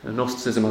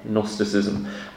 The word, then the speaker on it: Gnosticism: Gnosticism has a silent G at the beginning, so it's G-N, Gnosticism.